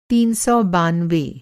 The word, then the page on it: तीन सौ बानवे: تین سوبیانوے